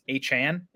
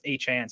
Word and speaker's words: Achane: I guess, by the way, it's Achane is how he wants it pronounced. – That's what I was just starting to get, you know, get the pronunciation down. Now it's Achane, so I guess we should start working on that.